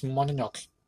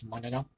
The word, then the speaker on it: Mononoke: So the next one will be Princess Mononoke. Mononoke. How do you would pronounce this? Well, I know English, they don't pronounce the E at the end. So it will be Princess Mononoke.